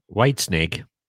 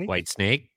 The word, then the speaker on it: Whitesnake: He would put the emphasis on the wrong syllable, and it would be as Whitesnake. – As if it were a question. – Yeah, Whitesnake? Like Whitesnake.